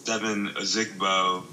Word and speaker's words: Ozigbo: How do you say his name? Do you know? – Okay, so Dare Ogunbowale signed with the Jaguars, and then Devin Ozigbo.